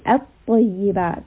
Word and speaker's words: الطيبات: I'm saying الطيبات. The lam sound is completely gone, even though it is there. So if you see in the Quran, there is a lam, but we are not pronouncing at all.